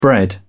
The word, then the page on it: bread: British English